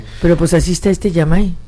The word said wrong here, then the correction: Imai: ¿así se pronuncia Yamai? – Imai.